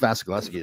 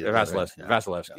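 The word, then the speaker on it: Vasilevskiy: But again, it's Vasilevsky. – Vasilevsky.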